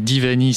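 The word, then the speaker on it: Diwani: Donc Divani, ça s'écrit avec un W, mais ça se prononce euh, V, si j'ai bien compris.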